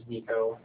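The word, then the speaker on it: Niko: It's spelled G-A-R-D-I-S-S, Frank. And my first name is Nico, and that's N-I-K-O.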